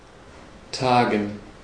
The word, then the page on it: meet: /miːt/